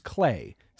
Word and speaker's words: CLAH: And I want to spend some time on this concept that Joel just mentioned called, pronounced CLAH, C-L-A-H. So you often hear about STEM, science, technology, engineering, math. CLAH is C-L-A-H, culture, language, arts, and humanities.